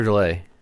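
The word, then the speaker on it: Dullea: Yeah, well, Keir Dulia, is that how you say his name? – Keir Delay. – Delay? I never always thought it was Dulia, but it's Delay, right? – Yeah.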